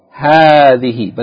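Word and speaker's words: hadhi (هذه): In this lesson we learn hadhi, which is the feminine of hadha, and it is pronounced elongating the first syllable, hadhi, but the aleph is omitted in writing.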